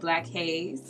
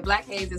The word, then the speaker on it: BlacHaz3: Well, guys, you can find me on everything, literally, at Black Hayes. So that's BLACHAZ3, not Black Chaz, because people will be thinking my name is Black Chaz.